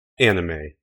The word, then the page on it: anime: American English